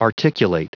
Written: articulate

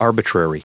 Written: arbitrary

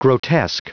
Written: grotesque

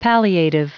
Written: palliative

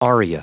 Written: aria

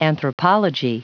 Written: anthropology

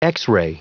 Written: x-ray

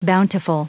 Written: bountiful

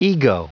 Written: ego

